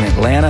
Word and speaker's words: Atlanta: They're down in Florida pronouncing both T's in Atlanta and thinking Spaghetti Junction is a pasta dish.